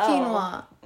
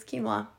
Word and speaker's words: quinoa: I told you it's quinoa. Oh. Mm. Oh my god, you probably didn't realize that it was that because of how it's spelled. – Yeah. – It's spelled quinoa. – Yeah. – Yep, and it's quinoa.